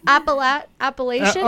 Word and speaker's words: Appalachian: The Apple Jacks pipeline. – Appalachian. – Appalachian. – The Appalachian pipeline.